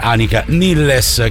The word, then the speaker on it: Annika Nilles: per Annika Nilles, che pronuncio così perché credo che sia la pronuncia tedesca.